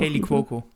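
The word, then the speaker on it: Kaley Cuoco: Mit Kelly Coco oder wie sie ausgesprochen Kelly Coco. Kelly Coco.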